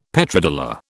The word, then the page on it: Petrodollar: British English